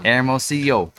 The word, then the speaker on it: Hermosillo: She took several years of French, and I need to work on Spanish because we did learn to say Hermosillo. Wait, did I say it right? – Hermosillo.